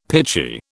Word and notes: pitchy: British English

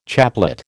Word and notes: chaplet: American English

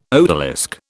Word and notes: odalisque: British English

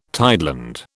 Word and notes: tideland: British English

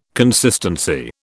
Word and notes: consistency: British English

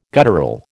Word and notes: guttural: American English

